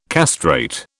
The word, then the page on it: castrate: British English